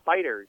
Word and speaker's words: FighterZ: I found out the other day, by the way, it's actually, even though there's a Z at the end, it's technically still pronounced like an S. – Really? – A lowercase S. Yeah, it's really weird, but because they wanted to have the Z in there, but it's technically pronounced just Dragon Ball FighterZ.